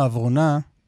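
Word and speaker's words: עברונה: לא, אבל לא, איציק לוי, אני עוצר אותך, כי את הדליפה בשמורת הטבע האברונה, אמרתי נכון, קלמן, אמרת מצוין. – מקפיד על ההגייה הנכונה.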